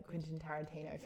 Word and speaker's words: Tarantino: It is a classic action Quentin Tarantino film.